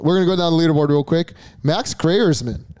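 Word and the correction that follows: Greyserman: Is it Graersman or Graserman? – It's Graserman.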